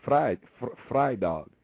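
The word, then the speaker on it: Vrydag: So like Friday, it's V-R-Y-D-A-G, but you don't say Friday. You say Fry Dog. You know what I'm saying? So the V is actually pronounced F, like an F.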